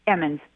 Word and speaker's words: Emmons: Emmons.